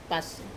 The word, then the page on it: pas: wamesa